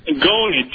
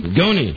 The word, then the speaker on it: Gony: Goni, G-O-N-Y. – Goni? – Goni. Yeah. – Like gonad? – Yeah. – I'm going to. How do you say your name again? – G-O-N-Y. – How do you, like Johnny with a G. – I'm Goni, F-U-R.